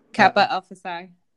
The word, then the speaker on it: Kappa Alpha Psi: Kappa Alpha Psi.